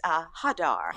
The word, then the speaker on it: Hadar: Her first name, I want to make sure that we're both saying it right, is Hadar. – Hadar. – It's H-A-D-A-R, yes.